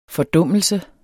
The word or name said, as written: fordummelse